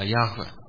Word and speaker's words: Yahweh: här är det hebraiska Jehova, det är Yahweh som är det rätta uttalet.